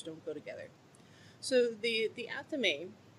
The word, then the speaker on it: athame: It's spelled A-T-H-A-M-E, and it is pronounced athame. Now, this is an old word, so that's why it sounds different than it looks.